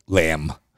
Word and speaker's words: lamb: Lamb. – lamb. So, so for those Israelis listening to us, you don't pronounce the B at right.